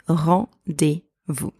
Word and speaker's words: rendez-vous: So now let's learn the pronunciation of the word rendezvous. So let's break it down first into syllables. Rendez-vous. Vous. Repeat after me. Rendez-vous.